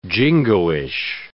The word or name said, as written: jingoish